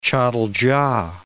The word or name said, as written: catalca